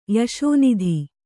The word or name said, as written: ಯಶೋನಿಧಿ